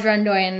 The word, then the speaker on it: Doyen: How do you pronounce it? – Yeah, it's called Run Doyen. That was correct.